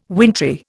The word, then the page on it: wintry: Indian